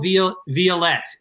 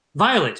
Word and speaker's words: Vialet: And so today on our show, we have the founder of the awesome organization Playworks with us, Jill Vialet. Did I say your name right? – It's just Vialet. – Vialet.